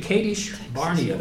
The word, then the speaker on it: Kadesh Barnea: By the way, Kadesh Barnea, that's the way you pronounce it.